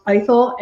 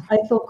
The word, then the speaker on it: Ithell: The first sentence of the book is, it's pronounced Ithell, and it's Ithell Colquhoun.